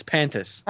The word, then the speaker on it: Pantis: This includes content that was not previously owned by WCW and was filmed independently by George Pantanta? – I think you're just Scott Steiner. – You added three syllables to that name. It's Pantis.